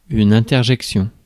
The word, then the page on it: interjection: France; [ɛ̃.tɛr.ʒɛk.sjɔ̃]